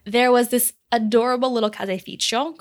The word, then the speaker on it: caseificio: Allora, uh, you have a third option actually for where you can get cheese, and that is from a caseificio, caseificio, which is spelled C A S E I F I C I O, caseificio, and that's a shop that is specializing in dairy products.